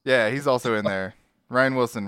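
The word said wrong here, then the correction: Rainn Wilson: Rain Wilson? It's spelled like rain.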